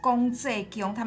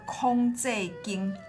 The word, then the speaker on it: 廣濟宮: 那广济宫哦，安平人哈，他们不是叫公济宫，他们叫做空济宫。 – 空界经对，有一个特殊的发音，很有趣。嗯嗯嗯。